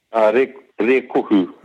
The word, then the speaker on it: Rēkohu: I think just a testament to you, to the Moriori living on Rikoko. Is that right? Did I pronounce that right? Rikoko? – Rikoku. – Rikoku. I'm going to stuff this. I'm so sorry. – It's K-O-H-U, Rikoku.